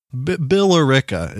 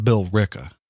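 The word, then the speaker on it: Billerica: It's B-I-L-L-E-R-I-C-A. – But I think so. Something like that. – So close to that. Whatever. Bill Rickett is what it looks like. – Mm. – That's not how it's pronounced. It's pronounced Bill Ricka. – B-I-L-L-R-A-I-C-K-A.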